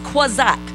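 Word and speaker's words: quazak (חזק): That word chazak for you students means this. It's actually pronounced quazak.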